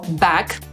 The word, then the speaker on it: back: Podsumowując, angielskie słowo back, które dosłownie znaczy z powrotem, wymawiamy przez samogłoskę pomiędzy polskim A a E.